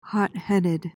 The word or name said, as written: hotheaded